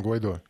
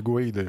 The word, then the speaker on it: Гуайдо: Как вы считаете, вот этот, э, вот этот Хуан Гуайдо, он, э, кстати, правильно произносим Гуайдо или Гуаида, его как, как его правильно произносить, не, не знаете? Гуайду. – Гуайдо все, Гуайду. Гуайдо все произносят.